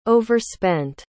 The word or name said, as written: overspent